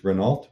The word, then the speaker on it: Renault: If you roll an R and the question is car type, you know, if I put, how do you say it? It's French, Renault.